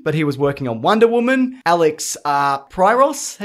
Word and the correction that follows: Proyas: How do you say that guy's name? – Who? – The guy who did Dark City. – Oh, Proyas. – Proyas, thank you.